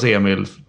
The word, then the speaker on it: Emil: Jag går väl inte och säger att hade du varit en romare så hade man uttalat Emil på ett annat sätt. Ja, men mitt namn uttalas Emil. Ja, men då köper jag väl det. Hur svårt ska det vara? – I Danmark hade det varit Emil.